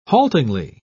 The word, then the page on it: haltingly: British English